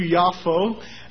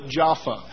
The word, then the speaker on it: Yafo: In Arabic, it is called Yaffa. In Hebrew, Yafo. And in English, generally people butcher the Arabic and call it Jaffa.